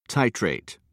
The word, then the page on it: titrate: British English